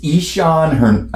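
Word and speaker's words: Ixan: It's Ishan Henderson. It's I-X-A-N.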